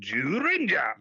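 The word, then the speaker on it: Zyuranger: They updated, instead of using Bioman footage, they took footage from Super Sentai Jew Ranger. – Yes, Kyori Sentai Jew Ranger. – Jew Ranger. – I love that show. – which I believe is the proper pronunciation, Jurinja.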